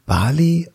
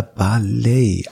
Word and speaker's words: Bali: Ähm, ist eigentlich Bali oder Ballet? Also weil ich habe natürlich, es ist ja, ich habe mir die Eselsbrücke gebaut wie Harley Davidson.